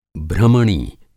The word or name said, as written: भ्रमणी